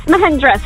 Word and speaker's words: Mahindra: Yes, Mahindra. Same thing. We'll go with that. – All right, gets that because he did his home thing first. And he said it right.